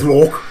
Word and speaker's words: loch: They wouldn't call it a lake in Scotland. – It's called a loch. Okay. – Yes. – Loch. – Okay. That's because they can't pronounce lake. Just because they can't pronounce lake. It's loch.